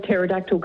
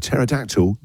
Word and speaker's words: pterodactyl: Why can't you hear a pterodactyl go to the toilet? – I don't know. Why can't you hear a pterodactyl go to the toilet? – Because the P is silent.